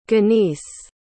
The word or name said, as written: ganice